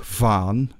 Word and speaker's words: Van: You pronounce the van like van, which is a V and an F. So it's van. Van, which is a bit like the count from Sesame Street, Van, tall.